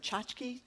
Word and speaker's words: tchotchke: Maggie Haberman, great reporter for the New York Times, has talked about how do you say that word, tchotchke? – Tchotchke defense.